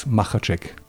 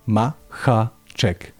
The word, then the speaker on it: Makacek: Alex Makacek. Makacek. – Yeah, okay. – The CH sound, you don't have that.